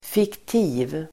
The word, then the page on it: fiktiv: [fikt'i:v]